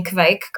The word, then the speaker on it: Kveik: Am I pronouncing Kveik correctly? – Yeah.